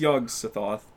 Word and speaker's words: Yog-Sothoth: Yeah, it's Yog-Sothoth, but it's like, do you ever play Skyrim? – Yep.